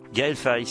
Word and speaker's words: Gaël Faye: Vous me corrigez si je prononce mal le nom du troisième garçon, Gaël Faye. – Gaël Faye, c'est ça.